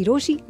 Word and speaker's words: Rochie: मेरा नाम है मिर्ची रोशी.